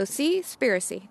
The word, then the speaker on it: Seaspiracy: That's a really hard word to pronounce. It's conspiracy and sea. So Seaspiracy. There we go.